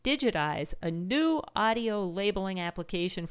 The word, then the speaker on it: Digit-Eyes: And as you can imagine, Digitize isn't spelled like the word digitize. It's actually spelled D-I-G-I-T hyphen E-Y-E-S.